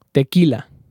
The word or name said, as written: Tequila